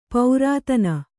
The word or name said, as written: ಪೌರಾತನ